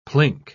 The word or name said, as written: plink